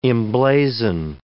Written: emblazon

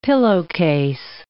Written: pillowcase

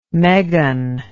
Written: megan

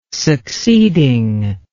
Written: succeeding

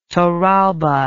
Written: torralba

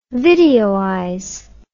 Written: videoize

